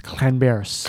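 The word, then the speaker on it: Llanberis: And I could get this wrong, but I think Lamberis, as you pronounce it, is Clanberis. – Clanberis. – It's like double L, I think, and it sounds like a bit like that. – Yeah.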